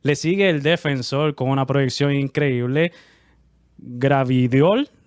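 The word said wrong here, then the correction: Gvardiol: ¿lo pronuncié bien, Pablo? – Cosco Guardiol.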